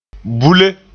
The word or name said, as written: bule